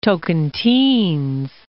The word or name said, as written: Tocantins